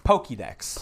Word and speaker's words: Pokédex: Pokemon Dex... Am I saying that right? – Pokédex. – Pokédex.